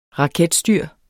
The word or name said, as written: raketstyr